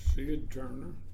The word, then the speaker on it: Sid Turner: My name is Sid Turner, S-I-D-T-U-R-N-E-R.